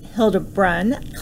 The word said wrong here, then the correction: Hilburn: Close. – I knew I would butcher it, Tamie. – Jay Hilburn. – Yay! – Say it again. – Jay Hilbert. – Good job.